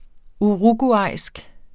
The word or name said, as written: uruguayansk